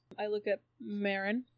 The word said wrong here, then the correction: Myrin: Mirin. – Mm-hmm. – And I say, should spell more of my names. His name is spelled M-Y-R-I-N.